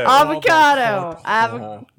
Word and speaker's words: avocado: Avocado. – Long A, not an E. Long A.